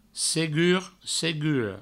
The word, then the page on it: segur, segura: segur, seguro